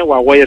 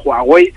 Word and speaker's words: Huawei: Es decir, Huawei se escribe con H, Huawei es la pronunciación en chino, los nacionales le ponemos una J y es Huawei.